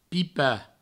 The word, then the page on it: pipar: pipa